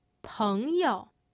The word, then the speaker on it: 朋友: 朋友. 朋友。 – Note that again, the first syllable has a tone, the second syllable doesn't.